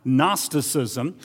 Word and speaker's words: Gnosticism: It looks like Gnosticism, but the G is silent, Gnosticism. And the word comes from the Greek word for knowledge, which is gnosis.